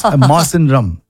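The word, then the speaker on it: Mawsynram: Tura, Jawai, and Masandone, and I'm pretty sure we are not pronouncing it right. – You're not pronouncing it right. – Oh, do you think so? – Because I went to YouTube and said how to pronounce. – Oh. – It's Masandrum.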